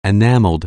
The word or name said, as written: enactment